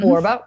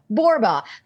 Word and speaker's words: Borba: Well, I'm Michele Borba, but I'm a 1L Michele, and Borba rhymes with Zorba. So it's Michele Borba.